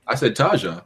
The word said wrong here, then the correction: Taha: I thought nigga, I thought you pronounced the J. It's Taha, my bad.